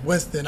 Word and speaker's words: Winston: So they'll try to pronounce my name, and I'll be like, it's Winston.